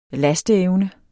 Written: lasteevne

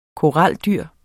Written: koraldyr

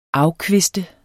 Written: afkviste

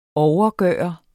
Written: overgøre

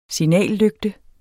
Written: signallygte

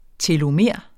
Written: telomer

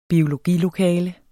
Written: biologilokale

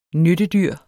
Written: nyttedyr

